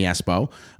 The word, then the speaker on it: Espo: Uh, and me personally, at Jimmie Espo, uh, and Jimmy is spelled I E.